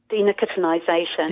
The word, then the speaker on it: Denicotinisation: Denicotisation, am I saying that right? Denicotisation? – Denicotinisation. – Denicotinisation, yeah. Okay.